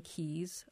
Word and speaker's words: quay: There's the effort to preserve the battleship mooring keys. Again, is it key, kay, or quay? Well, actually, they're all correct. I prefer the British pronunciation of key.